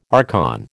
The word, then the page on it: archon: American English